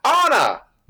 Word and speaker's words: Anna: You have mispronounced the name. – I have. Anna! Like the Frozen character.